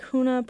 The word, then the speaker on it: Hoonah: The Hoonah Borough petition, pronounced like Hoonah but with a slightly guttural H, would form Alaska's 20th borough.